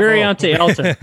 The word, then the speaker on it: Variante Alta: So he's pushing that braking zone, heading up into Variante Alta. Is that how you pronounce yes. – it? I'm the worst. – Bravo. – Variante Alta.